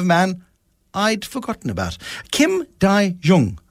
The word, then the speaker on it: Kim Dae-jung: So I would say Kim Dae-jung,